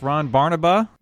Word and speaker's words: Ron Barnaba: Today we have a very special guest, Ron Barnaba.